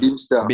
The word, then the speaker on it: Bimstr: Pour ceux qui ne connaissent pas, ça s'écrit B-I-M-S-T-R préfère préciser parce que euh, la façon dont on prononce n'a rien à voir avec la façon avec laquelle on l'écrit. C'est très laid, là b i B-I-M-S-T-R. Bimster.